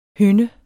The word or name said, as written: hynde